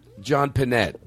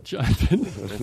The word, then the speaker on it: Pinette: Maybe it is I can call John Pinney. – John Pinette. – John Pinette. – It sounds like... – Wait, did I say it wrong? – You know. – No, you said it right, but I hadn't.